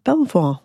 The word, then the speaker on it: Belvoir: Or Beaver, as we call it. – Oh, it's called... Oh, God. Sorry. Have I pronounced it wrong all the way through? – No. There's no right or wrong. – Beaver, okay. – Locally, everyone calls it Beaver, and everyone else calls it Belvoir.